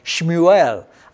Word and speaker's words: Shmuel: In Hebrew, we would call his name today Shmuel. I have friends there in Israel who are named Samuel. We call them Shmuel. That's how you pronounce that in Hebrew.